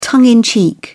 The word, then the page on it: tongue in cheek: British English